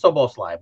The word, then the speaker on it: Szoboszlai: Because Szoboszlai, baby, just ignore the Z's and it's a breeze, like I said this morning. It's very easy to pronounce once you ignore the Z. This is Szoboszlai.